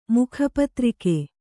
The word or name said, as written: ಮುಖಪತ್ರಿಕೆ